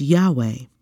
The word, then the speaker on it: Yahweh: Many Greek transcriptions also indicate that YHWH should be pronounced Yahweh.